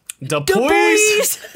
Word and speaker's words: Dupuis: It's D-U-P-U-I-S. Cool. How do you pronounce that? Dupuis? – Dupuis? – Dupuis. – Dupuis. – Dupuis. Dupuis. It's Dupuis.